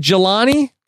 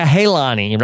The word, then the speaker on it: Jelani: Is it Jelani? – Yeah, I think so. – It's not like a Halani, right? – No, I don't think so. – Okay. – That's a hard J.